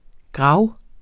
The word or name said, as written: GRAVES